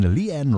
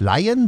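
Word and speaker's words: Lian: Unter anderem jetzt hier von Leanne Ross. Ja, die heißt nicht Lion, sondern Leanne, so möchte sie ausgesprochen werden. Ja, obwohl sie anders geschrieben wird.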